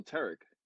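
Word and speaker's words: Tareq: And they got to me and I said, oh, Tarek.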